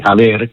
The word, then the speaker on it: haber: Va a saber o non va a saber, c'è una H di differenza, la pronuncia è praticamente la stessa, perché aver, che si scrive H-A-B-E-R, la B si pronuncia V e quindi diventa aver.